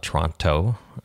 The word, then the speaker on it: Toronto: Toronto, I am told, is the proper way to say that.